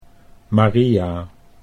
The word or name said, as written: Maria